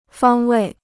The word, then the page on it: 方位: fāng wèi